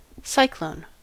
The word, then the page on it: cyclone: American English